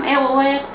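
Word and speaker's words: RER: اق او اق تلفظ در واقع فرانسوی آر ای آر هست که حالا سرواژه‌های اون سیستم قطار داخلی فرانسه هستش.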